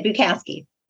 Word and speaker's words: Bukowski: Did I say that correctly? Because I realize I probably should have asked. – That's okay. Yes, you did. Bukowski.